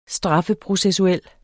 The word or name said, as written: straffeprocessuel